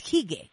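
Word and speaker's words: hygge: que es hige, que se escribe H-Y, doble G-E.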